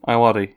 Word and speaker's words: Ayoade: Ayoade? Is that how you pronounce his surname? – Ayoade, yeah.